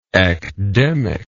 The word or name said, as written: ecdemic